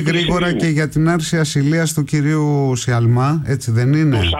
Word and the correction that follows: Σαλμά: Σαλμά, συγγνώμη για το γιώτα που εμφυλοχώρησε. Στην προφορά μου.